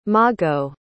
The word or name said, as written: mago